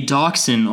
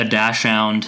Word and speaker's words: dachshund: A dachshund, or as I like to call it, a dachshund. – Which is incorrect. – But it is noted on the Wikipedia page that it has been mispronounced really often, very often, by English speakers because dachshund, or dachshund as it's supposed to be pronounced, but it's spelled dachshund, is of German origin, which means badger dog.